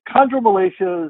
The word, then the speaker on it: chondromalacia: I was going to let you say chondromalacia. Did I get it right? – Perfect. Chondromalacia. Very good. – I got it right. Now, what is that? – So chondromalacia is just our,